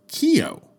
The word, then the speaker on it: Kio: If the N is not illuminated, then you get Kio. That is the official pronunciation, by the way, of the name. If you see it on posters, it reads capital K, then you see a backwards N, and then the letter O. This is from the Cyrillic alphabet, but it's pronounced Kio.